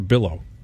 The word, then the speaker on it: billow: Like, as just a reporter, when smoke starts to bellow a little bit, am I saying that right? Bellow or billow? – Billow.